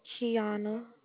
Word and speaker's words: Keona: Kiana. – Can you spell that for me? – K-E-O-N-A. – K-E-O-N-A? – Yes.